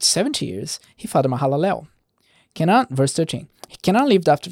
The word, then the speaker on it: Kenan: It's Kenan. Kenan. Because it's in Hebrew, the accent is always on the last syllable, unless there's an accent there saying it's in the syllable.